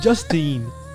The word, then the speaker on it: Justine: Yeah, because your dumbass doesn't know how to pronounce Justine. It's Justine.